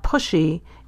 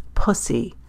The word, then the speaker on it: pussy: that you were actually distorting the fricative S sound. It's not pushy, it's pussy. Say it correctly.